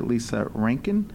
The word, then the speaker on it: Lisa Rankin: No, I wanted to also point out that this list is by Dr. Lisa Rankin. – Cool. Yeah. – I don't know if I said that right. – Sounded good.